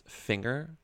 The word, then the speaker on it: finger: It's like how my dad says that finger is not pronounced with the hard G. He says it's technically pronounced finger, like singer.